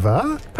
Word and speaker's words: Quiva: Quiva. – Pronounce the V. It's a hard V.